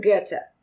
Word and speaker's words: Goethe: Goethe. It's German. – See it? I would have said Goethe. – I was going to say Goethe.